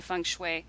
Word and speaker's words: feng shui: I always thought it was feng shui. Feng shui. I'm so glad I did not say that too many times out in public. Feng shui is how it's pronounced, huh? – Feng shui, that's right.